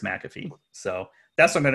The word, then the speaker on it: McAfee: Well, so it's named after John McAfee, and I believe I've heard him pronounce him, his na- own name as McAfee.